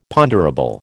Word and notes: ponderable: American English

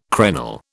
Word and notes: crenel: British English

